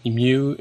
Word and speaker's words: mu: And then the formula there is you know F F equals uh, what's that mu mu? How do you say mu? – Mu Yep. Yeah, it was the coefficient of friction.